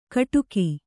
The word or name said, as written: ಕಿಡಿಕೆ